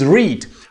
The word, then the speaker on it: read: Mucho cuidado que aquí sí es read. ¿Por qué? Porque es la pronunciación en presente. Read and read.